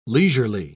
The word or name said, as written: leisurely